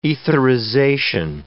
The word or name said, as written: etherization